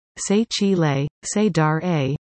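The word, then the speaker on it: Sechile Sedaira: In the native tongue of the Dene First Nation, Sechil Sadair, pronounced Se Chi Le, Se Dar A, means my younger brother, my older sister.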